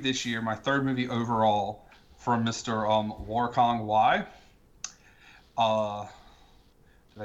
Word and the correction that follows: Wong Kar Wai: Well, I say Wong Kar Wai, but tomato, tomato. – Yeah, I think it's Wong Kar Wai, but who the hell?